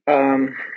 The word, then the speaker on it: Weil: So his name is spelled W-E-I-L. And in French tradition, we pronounce it Weil. – Oh, okay. So that's my mistake. – Whereas Hermann Weyl, last name is spelled W-E-Y-L. And in the German tradition, we say Weil.